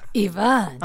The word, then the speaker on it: Iván: So I go by Iván, the Spanish pronunciation.